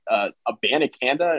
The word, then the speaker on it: Abanikanda: I do like, and I make fun of Pitt's running game a lot, but I like Abanikanda.